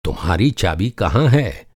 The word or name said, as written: चाबी